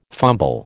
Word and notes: fumble: American English

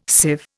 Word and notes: sieve: Indian

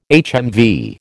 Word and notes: HMV: American English

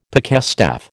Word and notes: pikestaff: American English

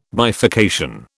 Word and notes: bifurcation: British English

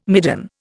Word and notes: midden: Indian

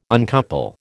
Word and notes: uncouple: American English

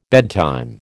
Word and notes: bedtime: American English

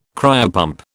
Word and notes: cryopump: British English

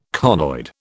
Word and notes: conoid: British English

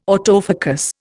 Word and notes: autofocus: Indian

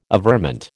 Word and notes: averment: American English

